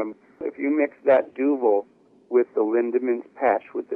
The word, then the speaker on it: Duvel: I'm still drinking Duvel? – Well, Duvel, yeah. – Duvel, thank you. I'll pronounce it properly, but I'll continue to drink it.